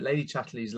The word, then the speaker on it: Chatterley's: But then you've got, I mean, completely in different end of the scale, Lady Chatterley's, am I pronouncing that right? Lady Chatterley's Lover. – Yeah.